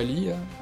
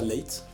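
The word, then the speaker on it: Leigh: Ça se prononce pas Lee. Enfin, dans le film, il me semble qu'il prononce ça Leight.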